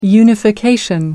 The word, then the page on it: unification: British English